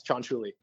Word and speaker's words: Cianciulli: Oh, it's just like it's spelled. – Yeah. There's 13 vowels in a row, bro. – That was really good. Yeah, it's Cianciulli.